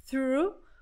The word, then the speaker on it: through: The company supplies gas through. Ó, que palavrinha legal também pra gente praticar a pronúncia. Through, through. TH, linguinha entre os dentes.